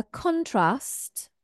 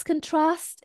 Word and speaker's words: contrast: So if it's a noun, this word here falls on the first, stress falls on the first syllable. A contrast or a contrast, if you prefer the American way. In contrast to the sunny weather yesterday, today is cloudy and rainy. In contrast, okay? But when it's a verb, it's an action. Let's contrast these two ideas. Contrast, trust is our stress. Okay, it's a second syllable stress.